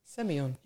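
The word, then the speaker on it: Sémillon: Now, this is just the pronunciation of this. Got to be careful. Semillon. – It's very simple. Semillon.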